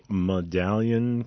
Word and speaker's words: Madalian: M A D A L I A N Madalian Chiropractic.